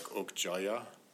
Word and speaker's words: Okja: You notice how Okja and Mija, the name of the young girl, in the Korean pronunciations, it was something like Okjaya and Mijaya.